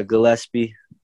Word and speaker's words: Gillespie: Colin Gillespie, is that how you say his name? – Yeah, Gillespie, guard out of Villanova.